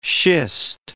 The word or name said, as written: schist